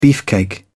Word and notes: beefcake: British English

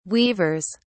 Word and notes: weavers: British English

